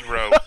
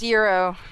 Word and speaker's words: gyro: I can go for a gyro right about now. – Damn right. – It's pronounced gyro. – Not at my job! Okay. – It is Euro.